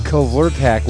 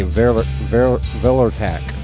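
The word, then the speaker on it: Kvelertak: It's spelled G-O-J-I-R-A. Gojira. – Gojira. – Oh no, Gojira! Gojira. – I know. Go ahead. – And Kvortak, which I think is a silent K. Velertak. Velertak, that's right.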